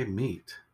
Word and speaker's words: meet: Or is it pronounced mate? – Well, I'd say meet. As in meet somebody.